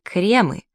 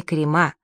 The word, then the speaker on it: кремы: Попутно заметим, что во множественном числе слово «крем» произносится как «кремы», но никогда не «крема», что нередко можно услышать в эфире.